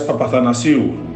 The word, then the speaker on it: Papathanasiou: Y en todo caso, el nombre pronunciado es Vangelos Odiseas Papathanasiou.